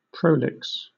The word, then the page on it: prolix: British English